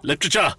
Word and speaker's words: literature: Literature. – It's two syllables. Literature. – Yeah. Literature. – There's no writ in the middle.